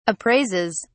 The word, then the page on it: appraises: British English